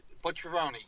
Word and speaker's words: Bucceroni: Bucceroni.